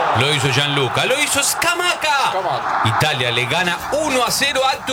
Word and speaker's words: Scamacca: Scamacca. – Qué bien que lo dice, fiscal. – Es como se pronuncia, no es que yo lo digo bien o mal.